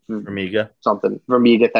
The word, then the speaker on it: Farmiga: Farmiga, thank you.